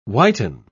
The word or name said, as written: whiten